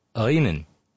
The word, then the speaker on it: Lenin: And it's actually more like a Lenin. Like saying L and Y at the same time.